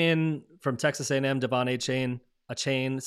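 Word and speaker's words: Achane: Somebody said earlier, I was listening to a podcast, I said Achan. I don't know how to pronounce He corrected it. – He must have, after everybody was like saying his name. I'm sure he probably dropped it. He's like, that's Achain is not my name, it's Achan.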